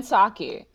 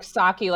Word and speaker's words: Psaki: That's Jen Psaki. So Psaki, okay. – The P is silent, and it's like Psaki, like Psaki bomb. – Okay. – Like the beverage.